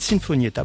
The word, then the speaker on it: Sinfonietta: Le 5 novembre 2026, Philippe Catherine se produira à l'Auditorium Stravinsky de Montreux, accompagné par les 60 musiciens d'une Sinfonietta de Lausanne, Sinfonietta pour bien le prononcer, voilà, pas avec la bouche en chantier.